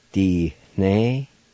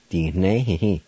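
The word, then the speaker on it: dinehehe: D-ne-he-he. D-ne-he-he. D-ne-he-he.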